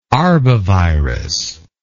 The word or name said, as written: arbovirology